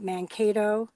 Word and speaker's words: Mankato: And even just learning about things like what happened, and correct me if I'm wrong, the massacre at Mankato.